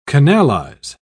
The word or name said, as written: canalize